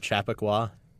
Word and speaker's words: Chappaqua: That was Hank and Dichter from Chappaqua, New York. I think I said it right this time. – I think you did.